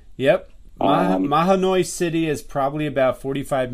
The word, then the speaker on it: Mahanoy: In Manoy City. Manoy. How do you say it? Manoy City? – Mahanoy. – Mahanoy City.